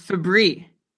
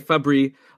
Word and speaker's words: Fabry: First of all, you're saying Fabry, I'm saying Fabry. How confident are you in the pronunciation? – I am fairly confident that it's Fabry. – Okay, so I will say Fabry, like the cleaning product, right? Febreze? – Yeah.